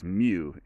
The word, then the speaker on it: Mu: I hear some people call it Mu, like a cow, but it's Mu, M-Y-U pronounced. Anyways, like that one's coming.